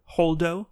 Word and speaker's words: Holdo: Holdo. – Holdo. Hold up. – H-O-L-D-O.